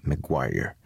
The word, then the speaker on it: McGuire: Un de ceux qui va fustiger et corriger la langue d'ici est Thomas McGuire, à prononcer McGuire, comme la rue à Québec.